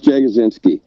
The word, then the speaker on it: Jagodzinski: All right, it's Jeff Jagodzinski.